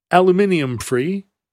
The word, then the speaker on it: aluminium: Or as our friends in Inglang would say, aluminium free.